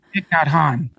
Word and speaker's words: Thich Nhat Hanh: Wynn Bruce was a climate activist and follower of, and I apologize if I'm saying this wrong, Thich Nhat Hanh. – Thich Nhat Hanh.